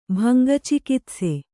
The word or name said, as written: ಭಂಗಚಿಕಿತ್ಸೆ